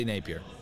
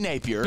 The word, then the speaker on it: Napier: You know, I get Virginia Tech vibes for Billy Napier, and I've got to say that right. Napier. For some reason, I want to say Napier, but it's not. It's Billy Napier. I've got to be better at that.